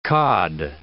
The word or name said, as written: cod